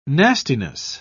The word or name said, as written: nastiness